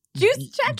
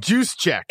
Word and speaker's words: Juszczyk: Kyle Juszczyk. – Juszczyk. – Juszczyk.